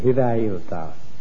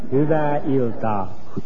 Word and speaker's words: Hyvää iltaa: Hyvää iltaa. – Give me that again. – Hyvää iltaa.